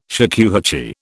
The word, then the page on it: shakuhachi: British English